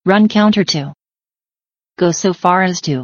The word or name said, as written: run counter to